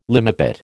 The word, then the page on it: limepit: American English